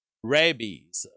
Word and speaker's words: rabies: Rabies. Rabies is how you say the disease, rabies.